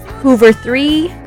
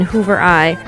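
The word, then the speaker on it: Hoover III: Hoover 3 with C. I did not know how to pronounce that until today. I was always saying Hoover I. That's H-O-O-V-E-R-I-I-I. Now I know.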